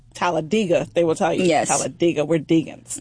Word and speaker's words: Talladega: So let's talk about this history-making thing that you did with Talladega. What's it called? How you say it? – Talladega, but actually... People in Alabama is actually Talladega.